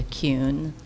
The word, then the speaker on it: Kuhn: For the record, my name is Julia Kuhn. Last name is spelled K-U-H-N.